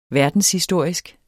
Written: verdenshistorisk

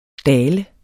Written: daler

